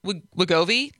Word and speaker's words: Wegovy: and Wegovy.